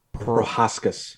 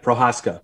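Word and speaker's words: Procházka: So, how do you say the last name of the guy that beat Dominic Reyes last night? – Prohaska. – Prohaska? – I believe.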